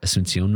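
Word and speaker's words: Asunción: And you read the Paraguay Asuncion mission.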